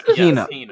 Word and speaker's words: Hina: It's Hina.